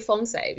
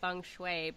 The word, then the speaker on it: feng shui: And so for example, how feng shui is pronounced by my husband, who's mainland Chinese and speaks quote unquote proper Chinese, he'll add that R, that shui. I can't even do it, that shui sound, right? I'm from southern Taiwan, and they have a very distinct dialect, and their pronunciation of Mandarin Chinese is totally different from mainland pronunciation of Mandarin Chinese. So I say feng shui because it's kind of got that Taiwanese twang to it.